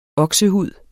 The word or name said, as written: oksehud